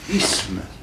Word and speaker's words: isme: Mais la prononciation correcte, c'est isme. Là, il faut travailler et le maintenir. – Les sud de la Loire, tous les deux. – Eh oui, non, je sais bien, vous prononcez isme.